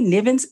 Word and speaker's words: Nivens: Nivens is N-I-V-E-N-S.